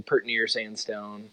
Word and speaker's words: Pert Near Sandstone: That's the Midwestern pronunciation, Pert near Sandstone,